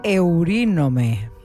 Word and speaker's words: Eurínome: A ver si lo digo bien. Eurínome. Esta vez lo dije bien.